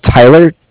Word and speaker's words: Tyler: My name is Tyler, T-Y-L-E-R, and I'm calling with Cardiff. It's spelled C-A-R-D-I-F-F, as in Frank, Frank.